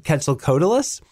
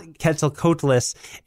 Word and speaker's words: Quetzalcoatlus: Anyway, the pronunciation is not Quetzalcoatlus. It is closer to Quetzalcoatl.